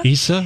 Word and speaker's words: Eissa: I'm not sure how you pronounce it. E-I-S-S-A. – I think it's Issa. – Issa.